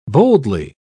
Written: boldly

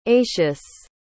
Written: atius

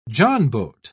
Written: johnboat

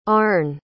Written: Arne

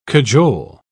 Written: cajole

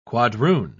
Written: quadroon

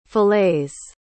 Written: filets

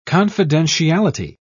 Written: confidentiality

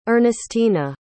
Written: earnestyna

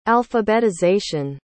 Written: alphabetization

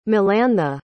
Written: melantha

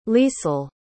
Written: liesel

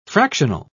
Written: fractional

